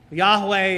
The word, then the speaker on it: Yahweh: Yahweh is the likely way to pronounce what then we get translated in our English copies with the Lord, all caps.